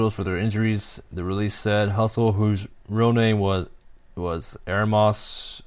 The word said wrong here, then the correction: Ermias: Shandon. I'm sorry I pronounced it. His first name is Ermias, and the last name is Asghedom.